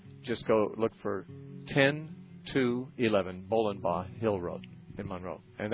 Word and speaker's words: Bollenbaugh: Bolenbaugh is spelled B-O-L-L-E-N-B-A-U-G-H. Sounds like bowling ball, but it's Bolenbaugh Hill Road in Monroe.